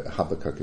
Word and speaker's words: Habakkuk: it's in the Old Testament, but chapter 1, verse 13 of Habakkuk, or Habakkuk as I've heard it pronounced. I have no idea why. Habakkuk is what I'm going to say.